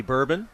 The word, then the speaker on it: Bourbon: On deck is Willie Bourbon, the first baseman. Thought it was funny in the pronunciation guide, they had bourbon there, and it says, like the drink.